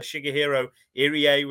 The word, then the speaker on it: Shigehiro Irie: And then, of course, we had Shigehiro Irie, was the pronunciation, Jonas.